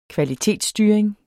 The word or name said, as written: kvalitetsstyring